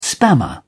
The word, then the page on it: spammer: British English